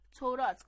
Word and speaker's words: تورا: با توجه به ارزش و جایگاه این کتاب‌ها، اغلب به کل کتاب مقدس یهودی نیز تورات گفته می‌شود. تورات در اصل لفظی عبری است و تورا تلفظ می شود.